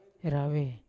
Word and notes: rave: Yawa

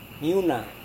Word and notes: miuna: Serewen

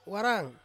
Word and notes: warang: Ansus

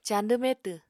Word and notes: cañdw metw: apatani

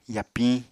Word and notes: yapiñ: Apatani

